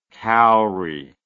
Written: cauri

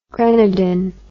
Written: grenadin

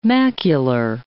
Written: macular

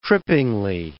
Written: trippingly

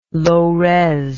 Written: lores

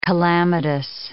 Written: calamitous

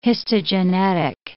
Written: histogenetic

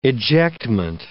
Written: ejectment

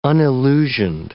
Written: unillusioned

